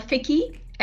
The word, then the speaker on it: FICCI: It's called FICCI. F-I-C-C-I.